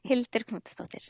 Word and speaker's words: Hildur Knútsdóttir: Hildur Knútsdóttir.